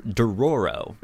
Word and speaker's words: Dororo: Um, so the the book is called Dor- Dororo. – Mm-hmm. I believe that's how you pronounce it. Uh, it's D O R O R O.